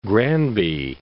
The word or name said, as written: Granby